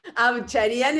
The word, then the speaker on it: Avcharian: Abcharian es el apellido, pero nadie lo pronuncia bien, quédate tranquila, es complicado, un apellido armenio rebuscado.